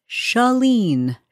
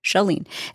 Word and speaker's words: Chalene: And in order to join it, you just need to know how to pronounce my name, which is Chalene. Shah. Not Shar, not Shay. Shah. Chalene.